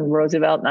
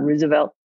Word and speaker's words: Roosevelt: your family would pronounce it Franklin Roosevelt, not Roosevelt. – Does anybody have a read on that? I always pronounce it Roosevelt. – I think Roosevelt is correct Dutch pronunciation.